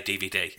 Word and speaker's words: DVD: But in Spain, you would say de, uve, de. – Yeah. – Whereas in Argentina, you would just say DVD.